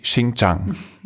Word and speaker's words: Xinjiang: Zum Beispiel durch Xinjiang.